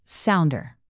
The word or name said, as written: sounder